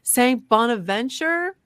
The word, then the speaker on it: Bonaventure: Saint Bonaventure University.